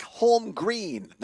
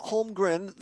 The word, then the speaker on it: Holm Green: We are live on location at the, let me get the name right here, Mark Holm Green, not Holmgren, the Mark Holm Green Memorial Boat Launch here in Black River Falls.